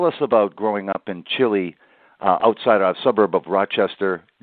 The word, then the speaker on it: Chile: Rhymes with Hi-Li. I grew up on a dirt road called Stallman Drive. It was off of Ballantyne Road, south of the city of Rochester, in the town of Chile.